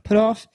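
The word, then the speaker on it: Puthoff: And then you get, I believe it was Dr. Hal Putoff. Is that how you say his name? – Yeah. – Putoff.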